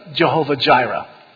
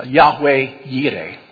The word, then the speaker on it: Yahweh Yireh: And we understand the phrase God will provide, as sometimes it's pronounced Jehovah Jireh. The only problem with that is that there are no J's in the Hebrew alphabet, so you can't pronounce it that way. It's probably better Yahweh Yireh.